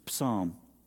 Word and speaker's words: Psalm: We pronounce the P. It's Psalm. If it's there, use it.